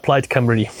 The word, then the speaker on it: Plaid Cymru: Plaid Cymru.